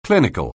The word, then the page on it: clinical: anglais